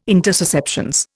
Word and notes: intussusceptions: Indian

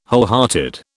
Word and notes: wholehearted: British English

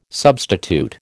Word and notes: substitute: American English

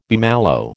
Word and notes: bummalo: American English

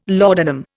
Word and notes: laudanum: Indian

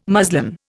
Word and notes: muslin: Indian

